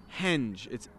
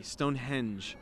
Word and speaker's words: Stonehenge: So did I say hinge? Henge. It's spelled with an E. Stonehenge.